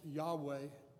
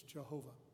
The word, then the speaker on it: Yahweh: It's pronounced Yahweh in some translations, Jehovah.